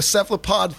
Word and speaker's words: cephalopod: Squid, octopus, cuttlefish, you know, the cephalopod family.